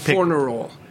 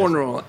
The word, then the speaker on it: Fornerol: Didier Fornerol. – Spell it. D-I-D-I-E-R. Spell the last... – Fornerol. F-O-R-N-E-R-O-L. – Okay.